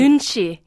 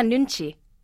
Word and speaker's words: nunchi: In the book, you allude to a Korean idea of nunchi. Am I saying that right? – Nunchi, yeah. Oh, very close. Yeah, nunchi.